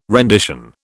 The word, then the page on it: rendition: British English